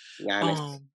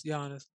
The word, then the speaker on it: Giannis: Giannis. – Yes, Giannis.